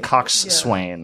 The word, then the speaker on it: coxswain: That's why I figure it was like front, back, and that word is spelled like coxswain. – Coxswain. – Yeah. But you pronounce it coxswain.